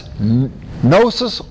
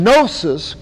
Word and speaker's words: gnosis: Now that's gnosis or gnosis. There's two ways to pronounce it. Usually English people say gnosis. But it's spelled G-N-O. Gnosis, okay? Gnosis. Now that is where I get it.